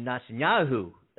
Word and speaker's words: Netanyahu: But the prime minister there, Benjamin Netanyahu,